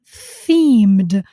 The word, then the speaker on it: themed: Pay attention to that word, Halloween themed. Let me spell it here for you. T-H-E-M-E-D, themed. Pay attention to the pronunciation, themed, themed. What does it mean exactly?